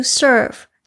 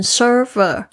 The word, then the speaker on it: serve: 那这个词其实就是 the purpose of life is to serve，就是去 serve。这个字呢是服务，大家特别注意一下，我的过去很多学生会念成 server，要特别注意一下后面这个 v 就停下来，因为它是动词。